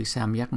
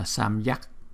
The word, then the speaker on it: samyak: Chúng ta đọc cái chữ samyak này, chúng ta không có đọc là samyak, chúng ta đọc là samyak. Chỉ cái chữ y dài này thành ra là hai chữ đó, là samyak, samyak, samyak Gatanam, Samyak Gatanam, Samyak Pratipannanam, Samyak Pratipannanam, Namo Devarshinam, Deva Devarshinam, Devarshinam, Devarshinam, Devarshinam.